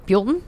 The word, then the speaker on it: Buellton: Buellton. – Is that how you say it? – Mm-hmm. It's not Buellton.